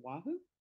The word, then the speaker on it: Wahoo: She's from, and I always say this town name wrong, is it Wahoo? – Yes, at least to my